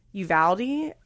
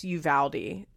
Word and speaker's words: Uvalde: And so I was like, oh my God, how do you say Uvalde? Uvalde? Oh, I already messed it up. Okay, it's Uvalde.